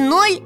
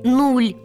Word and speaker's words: нуль: Мы ведь с вами произносим и ноль, и нуль. – И нуль, и ноль одинаково допустимые для употребления слова для цифры и для числа. Чаще под ударением мы говорим ноль, а без ударения – нуль.